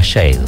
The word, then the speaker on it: Sade: Algunos le dicen Sade, Sade. Alguna vez un amigo experto en idiomas dijo que se pronuncia Shade.